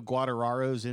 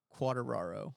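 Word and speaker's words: Quartararo: Guarantano? – Quateraro.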